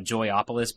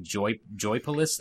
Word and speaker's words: Joypolis: I keep wanting to call it Joyopolis, but it's just Joypolis theme parks.